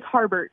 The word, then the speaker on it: Harbert: It's Harbert. – Harbert. Yeah. It's exactly like it's written.